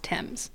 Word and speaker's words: Thames: It does, but it's pronounced Thames.